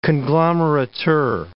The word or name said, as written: conglomerateur